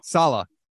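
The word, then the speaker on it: Saleh: Salah.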